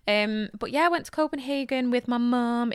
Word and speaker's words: Copenhagen: Oh, is that how they say it? – Yeah, Copenhagen. – Oh, really? Mm-hmm. I didn't know that. – Yeah. Yeah. I enjoyed it. – So they don't say Copenhagen? – No, not really. Oh. They have two more vowels than we do, and they pronounce things in a really different way.